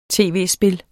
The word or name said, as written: tv-spil